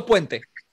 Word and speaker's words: Puente: But Anthrax, of course, was was great with uh, uh, uh what's the famous uh, Pente Pente uh, how do you pronounce his name? Percussionist. – Uh, uh Tito Puente. – Tito Puente, yes.